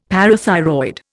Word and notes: parathyroid: Indian

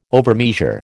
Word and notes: overmeasure: American English

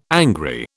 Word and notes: angry: British English

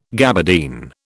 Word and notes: gabardine: British English

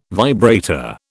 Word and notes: vibrator: British English

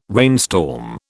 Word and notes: rainstorm: British English

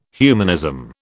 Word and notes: humanism: British English

